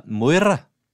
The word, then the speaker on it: Moira: Moira.